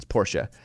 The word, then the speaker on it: Porsche: The real pronunciation is Porsche. And then finally, how about H-Y-U-N-D-A-I? – Hyundai.